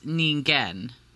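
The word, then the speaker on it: Ningen (人間): Okay, so this says Ningen, which is, I think, what you said. – It is what I said, yes. – Yes, Ningen.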